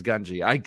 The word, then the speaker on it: Gungi: Which, by the way, the correct way to pronounce Gungi is Gungi. I hear so many people on YouTube. – It's a Gungi that say his name is Gungi, and I die every time. It's Gungi. It's Gungi, bro. Just say Gungi.